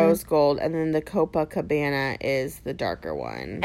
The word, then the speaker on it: Copacabana: But Brazilian Bum Bum Cream, Copacabana, I said it right, Copacabana Bronze Glow Oil.